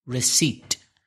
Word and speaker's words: receipt: Receipt. – How do you spell it? – R-E-C-E-I-P-T. – Difficult to pronounce for Spanish speakers, especially when you see it written. It's receipt. – With a silent P.